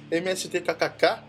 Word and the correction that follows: MST3K: assim? – MST-3K.